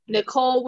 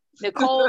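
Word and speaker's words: Nichole: Where is Nicole with an H. The H is silent. My mother was on drugs, so she added extra letters and shit. Nicole with an H on Facebook and Instagram.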